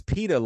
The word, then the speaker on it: pita: That's so it's pita like pita bread, P I T A. – No, it's not. It, it is like pita like that. That's how you say it.